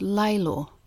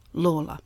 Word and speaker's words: Lawler: Now, listening to the previous episode, I note that all the way through I was pronouncing Peter Lawler's name as Laylor, despite my making a note to myself to use the pronunciation advised by the Australian Dictionary of Biography, Lawler.